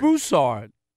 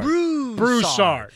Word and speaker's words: Broussard: It's Broussard. Chris Broussard. – Broussard.